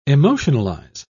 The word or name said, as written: emotionalize